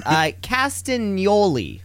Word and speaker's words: Castagnoli: Castagnoli. I think it's really important that you kind of turn the G and the N into a N.